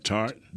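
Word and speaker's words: Tartt: And we're talking about Josh Norman, Kaywan Williams, free safety Jimmy Ward, and how do you pronounce his name? – Tart. – Yeah, Tark. Yeah. We know. We'll mess some names up here. Let me look at the pronunciation Tart. – Here. – Tart. – Jaquaski Tark.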